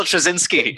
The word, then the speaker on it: Straczynski: Michael J. Stravosky. – J. Michael Straczynski. – J. Michael Straczynski. There we go.